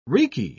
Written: reeky

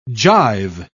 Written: jive

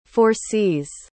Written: foresees

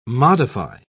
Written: modify

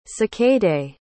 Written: cicadae

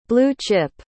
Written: blue-chip

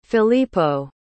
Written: filippo

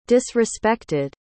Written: disrespected

